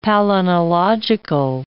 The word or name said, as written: palynological